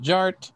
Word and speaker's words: Jart: The D is silent, so it's just Jart.